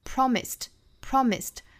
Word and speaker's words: promised: 尾部加的这个 ed 仍然是要读成清辅音 t promised